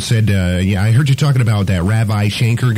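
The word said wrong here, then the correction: Ravi Shankar: Well, you mispronounced his name. His name is Ravi Shanker.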